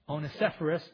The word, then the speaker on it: Onesiphorus: I can't say that right. Onesiphorus, thank you.